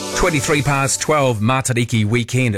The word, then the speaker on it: Matariki: It's a short sound, mata, riki. Don't elongate the sound.